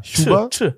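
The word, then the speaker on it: Chuba: Ist es so ein Sch oder so ein Sch? Es ist ja CH. – Ja, Schuba. Schuba. Schuba. – Wie Tschüss. – Tschüss. Nina Schuba.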